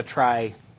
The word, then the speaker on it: trie: However you want to pronounce that, T-R-I-E. But it is a try structure, and the basic idea is just that I have this directory of directories.